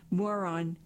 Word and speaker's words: moron: what Gordon likes to call moron construction, pronounced like the insult, holds together as it is moved.